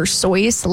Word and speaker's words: Seuss: Dr. Seuss is actually supposed to be pronounced like Dr. Soyce, like moisture.